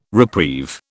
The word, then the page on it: reprieve: British English